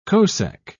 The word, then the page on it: cosec: British English